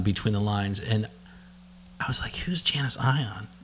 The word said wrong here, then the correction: Janis Ian: And so I looked up the Janis Ian. – Janis Ian, sorry. Janis Ian. See, I couldn't even say her name.